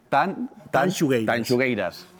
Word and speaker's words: Tanxugueiras: Tanxugueiras.